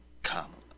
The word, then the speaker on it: Kamala: It's pronounced Kamala. I like how Bill actually pronounces it correctly, which no one does.